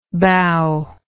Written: bao

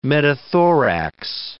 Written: metathorax

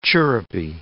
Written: chirrupy

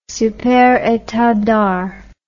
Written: Super Etendard

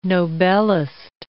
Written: Nobelist

